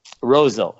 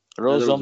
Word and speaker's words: Roseau: How do you say that? – Roseau. Yep. Roseau, Minnesota. – Roseau.